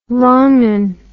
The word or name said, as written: wrongun